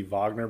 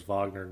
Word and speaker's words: Wagner: it would be Wagner because it's named after the composer, and the composer's name was Wagner, not Wagner, is, you know, they launched some type of insurrection of a sorts into, you know, the heart of Russia.